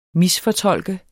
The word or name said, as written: misfortolke